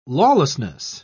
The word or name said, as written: lawlessness